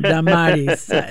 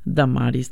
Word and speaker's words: Damaris: So two syllables, Dama, but yeah, it is Damaris.